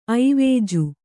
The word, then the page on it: ಐವಜು: kannada